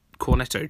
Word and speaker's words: Cornetto: And the same applies to my next recommendation the Three Flavors Cornet Cornetto trilogy, say that properly,